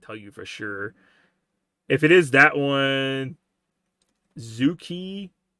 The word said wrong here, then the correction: Zhuque: Zuki? I don't know if I'm pronouncing that right. – Zhao Si? – It's Zhuque.